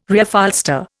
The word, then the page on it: Reupholster: Indian